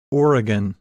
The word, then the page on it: Oregon: English